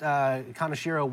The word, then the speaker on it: Kaneshiro: I only know because I spoke to his son. It's Kaneshiro. – Kaneshiro. Yeah, okay. – Yeah, sorry about that. Unfortunately, Staff Sergeant Kaneshiro was killed in Vietnam,